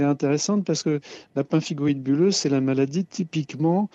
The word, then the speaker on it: pemphigoïde: Le traitement pour la pemphigoïde bulleuse, je l'ai déjà bien prononcé, c'est déjà quelque chose. – Alors, on dit plutôt pemphigoïde.